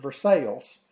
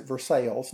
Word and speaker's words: Versailles: Versailles. Kentuckians pronounce it Versailles, not Versailles.